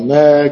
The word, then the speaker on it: ne'er: And crisp and crispian shall ne'er go by. Ne'er es una sola sílaba.